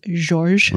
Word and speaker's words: Georges: It's George with an S. – I think it's a soft G. So it's George. – George. Rouault. – Rouault. – R-O-U-A-U-L-T.